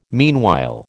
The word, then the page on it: meanwhile: American English